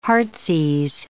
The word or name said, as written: heartsease